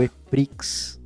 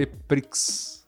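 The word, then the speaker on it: E-Prix: O EPRIX, não sei. EPRIX. EPRI. EPRI. Né, vamos, vamos usar a pronúncia correta aqui.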